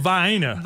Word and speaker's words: Vienna: It's called Vienna. – Vienna. See, I go after the little cute little sausage that's delicious, but it's Vienna, Georgia.